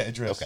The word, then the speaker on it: Idris: Yeah, Idris.